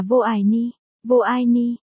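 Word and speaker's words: vô ai ni (我爱你): 520 là anh yêu em, tiếng Trung đọc là vô ai ni, 1314.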